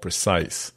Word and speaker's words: Precyes: Today, we're going to talk with one of the founders of a company called Precise. And it's spelled Precyes. And I'm going to mangle it later on in the podcast. But Precise, as the EYES part sort of emphasizes, is operating literally in ophthalmology.